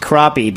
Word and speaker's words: crappie: It's pronounced crappie, but written crappy.